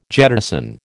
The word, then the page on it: jettison: American English